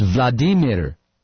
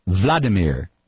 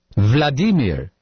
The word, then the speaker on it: Vladimir: In Russian, it's Vladimir, not Vladimir. Vladimir.